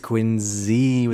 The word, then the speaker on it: Quincy: Q-U-I-N-C-Y. Q-U-I-N-C-Y. – Quincy. – Say it again. – Quincy. – You're close. – Yeah. Because it's Quincy. – It's Quincy with a Z.